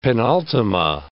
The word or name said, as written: penultima